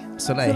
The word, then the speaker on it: Soleil: How do you say Soleil? – Soleil. – Soleil. – Cirque du Soleil. No, yeah. L's. Forget the L's. – Forget the L's. – Except the one in the middle.